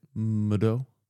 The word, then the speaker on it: Mido: I picked the Michaud, I think is actually how you say it. – Mido. – I think it's a shh with that D with the accent.